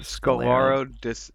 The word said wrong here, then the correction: scleroderma: no, scol- it's scleroderma. That's what it is.